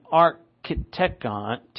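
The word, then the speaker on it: architekton: Sophos, which means wise, and architecton, it's a long O.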